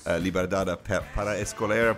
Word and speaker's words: Liberdade para Escolher: This week, again, joined as announced by Fabio Fernandes, your favorite co-host of all time, with Portuguese language podcast, Liberdade para Escolher. Probably mispronouncing that. Fabio, how's it going? – Hi. Always a pleasure to be here, and you pronounce it perfectly.